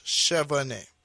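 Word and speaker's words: Chevannais: So, the proper French way to pronounce it is Chevanet, right? They spell it C-H-E-V-A-N-N-A-I-S, I believe. But coming from a family who is Jamaican,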